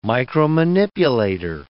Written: micromanipulator

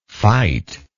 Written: fightingly

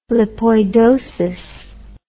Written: lipoidosis

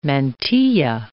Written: mantilla

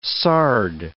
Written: sard

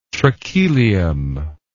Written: trachelium

